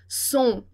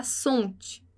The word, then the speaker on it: sont: E a gente não vai pronunciar, lembra lá, o T, o S e o D em final de palavra. Então, som, som, não vai falar sonte, vai falar som, som.